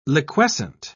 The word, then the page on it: liquescent: British English